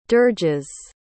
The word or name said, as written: dirges